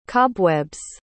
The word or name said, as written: cobwebs